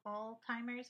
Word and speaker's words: Alzheimer's: It's like Alzheimer's. There's no T. – No, we're just going to. Technology is a godsend. – Where would we be without that? – Because I call it Alzheimer's all the time, and I get made fun of.